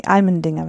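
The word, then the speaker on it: Allmendinger: Jutta Almendinger ist, ähm, nee, Almendinger wird es ausgesprochen.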